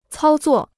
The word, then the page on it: 操作: cāo zuò